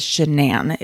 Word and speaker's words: Shanann: Her name has been said all different types of way, but her name, according to most, is Shanann, is how to pronounce it.